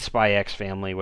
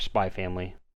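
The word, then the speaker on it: Spy × Family: I would say since Spy X Family, which obviously we have, or Spy Family. How dare I pronounce the X in an anime name? – How dare you?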